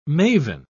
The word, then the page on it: mavin: British English